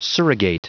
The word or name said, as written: surrogate